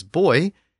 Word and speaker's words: buoy: And in some places, English speakers pronounce B-U-O-Y as boy, and in other places it's buoy.